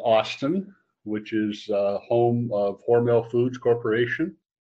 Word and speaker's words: Hormel: I remember studying Hormel Foods.